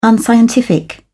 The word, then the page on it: unscientific: British English